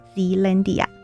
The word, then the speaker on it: Zealandia: 不妨可以试着用台语发音 Zealandia，听起来会很像热兰遮城哦。